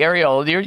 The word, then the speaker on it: areola: Okay, yes, yeah, the areola.